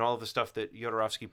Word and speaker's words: Jodorowsky: I, I remember looking up oh, how to pronounce it when we talked it's about Hispanic. It. It's like Yodorovsky.